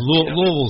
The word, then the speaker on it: Louisville: Well, see, I live in Kentucky, and I know Louisville is two syllables, so I can slur. You know, Louisville is Louisville. That's it. It's kind of like a stuck in a pigeon's throat, Louisville.